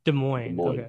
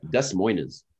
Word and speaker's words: Des Moines: Des Moines. – Des Moines, if you want to spell it phonetically.